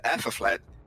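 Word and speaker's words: Æthelflæd: Que no caso, escreve-se Alteflaed, né? Mas é Alteflaed.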